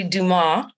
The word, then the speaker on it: Dumas: It's actually Dumas.